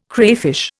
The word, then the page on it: crayfish: Indian